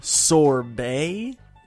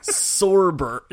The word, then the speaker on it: sorbet: Do you mean sorbet? – Is that how you pronounce that word? – Yes, it's not sorber.